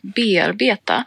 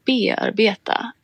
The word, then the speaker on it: bearbeta: Jo, det var så att jag hade en gång en tyskspråkig föreläsare på universitetet som konsekvent sa ordet bearbeta med betoning på andra stavelsen, alltså bearbeta, vilket ju är samma betoningsmönster som tyskan har på det ordet, alltså bearbeiten. – Ja. – Och det här fick mig att fundera på varför vi på svenska säger bearbeta med betoning på första stavelsen.